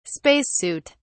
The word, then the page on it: space suit: British English